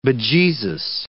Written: bejesus

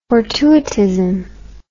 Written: fortuitism

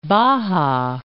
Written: Toa Baja

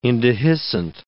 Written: indehiscent

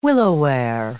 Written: willowware

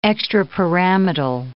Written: extrapyramidal

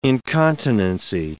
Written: incontinency

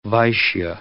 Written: Vaishya

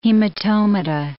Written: hematomata

